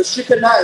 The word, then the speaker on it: Shekinah: Right here in the Holy of Holies is where the Shekinah, I know you've heard the Shekinah is how it's actually pronounced in the Hebrew.